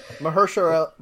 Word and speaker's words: Mahershala: No, there's no W in there. – Okay. Mahershala.